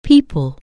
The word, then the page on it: people: anglais